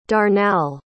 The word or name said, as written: darnall